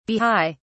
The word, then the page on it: bahai: British English